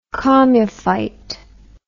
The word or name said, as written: chomophyte